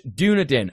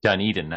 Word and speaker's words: Dunedin: Dunedin, actually. – Oh, Dunedin. Oh, that one. Oh, sorry. Yeah, you're right.